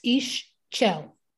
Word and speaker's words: Ixchel: So speaking of divinities, you have a relationship with Ixchel, is that correct? Is that how you pronounce her name? – Yes, Ixchel.